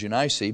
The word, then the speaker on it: Eunice: "When I call to remembrance the unfeigned faith that is in thee, which dwelt first in thy grandmother Lois and thy mother," we say Eunice, the pronunciation is Eunice, but Eunice, "and I am persuaded that in thee also."